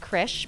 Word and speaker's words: Krzych: So I am Allison Krisch, pronounced Krisch, but spelled K-R-Z-Y-C-H.